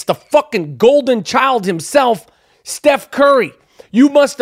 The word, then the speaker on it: Curry: Steph Curry, whose last name rhymes with Flurry.